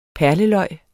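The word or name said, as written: perleløg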